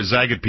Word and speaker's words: Zagat: The Zagat or Zagat people, depending on how you like to pronounce it, has a new guide out.